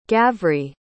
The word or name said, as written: gavrie